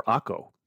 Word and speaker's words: Akko: It's Acre or Akko. A-K-K-O is the way it's spelled.